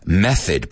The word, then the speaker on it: method: Method, porque he oído muchos alumnos decir method, right? It's method. There's a method. It's not myth.